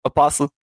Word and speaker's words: apostle: So you don't pronounce the T? – No. – No. – So any listeners out there, if you're saying apostle with a T, jail. Straight to jail.